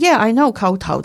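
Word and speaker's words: kowtow: And I said, in Cantonese, this is kao tao.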